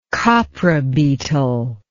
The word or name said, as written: khapra beetle